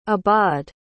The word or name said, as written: ibaad